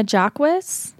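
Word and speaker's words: Jacques: One of the episodes we're going to do is highlighting Vienna Jacquis. It's spelled Vienna Jocks, but Connell points out it's pronounced Jacquis or Jackwith.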